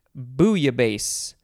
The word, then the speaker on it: bouillabaisse: Next we have bouillabaisse. Or bouillabaisse, or, yeah, I think that's good. I always thought you pronounce the L's in this one, but I guess you don't. B-O-U-I-L-L-A-B-A-I-S-S-E, for those of you who want to know how it is spelled.